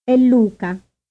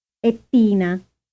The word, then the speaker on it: è: Listen carefully and repeat, noticing that any consonant that immediately follows the word e is pronounced strongly. – È Luca. È Tina. È Eva.